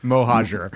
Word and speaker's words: Mohajer: And I'm saying it wrong. – Close enough. Mohajer.